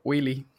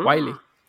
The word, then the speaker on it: Wiley: O Willy, como lo quieren mencionar. Ah. Wiley. Creo que se pronuncia Wiley. De hecho, encontré muchos lados donde lo pronuncian diferente. No recuerdo algún video en inglés, pero creo que lo, deja como Wiley.